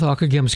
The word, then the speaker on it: Ockeghem: We'll begin with Akagem's Kyrie, Lord Have Mercy, and Gloria, Greater Doxology, of his Mass Ordinary.